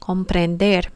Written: comprender

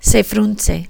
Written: se frunce

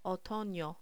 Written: otono

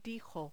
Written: dijo

